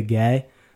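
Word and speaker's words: Guay: For what it's worth, her personal assistant said Rebecca Gay, but I just have like a mental block with pronouncing it that way. Like I always just said Gway, so I just keep saying Gway.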